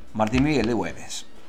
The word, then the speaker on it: Martín Miguel de Güemes: Hoy estamos recordando el fallecimiento de Martín Miguel de Güemes. ¿Lo dije bien? – Sí, señor. – Martín Miguel de Güemes.